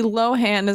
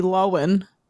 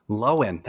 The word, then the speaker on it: Lohan: Lindsay Lo Lindsay Lohan is actually Lindsay Lowen. Lowen? – The H is, is silent, yeah.